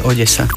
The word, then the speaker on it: Odesa: V slovenčine väčšinou D a E sa vyslovuje meko, D. A keď sa to má vysloviť tvrdo, tak je naznačená tvrdá výslovnosť. Takže ak tam nie je nič hra na tej zátvorke, znamená to, že sa vyslovuje Odesa.